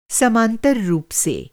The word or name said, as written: समांतर